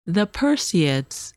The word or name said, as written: Perseids